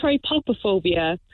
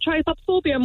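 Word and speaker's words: trypophobia: So I call it tripopophobia, but I've heard it pronounced other ways, but I think it is that way.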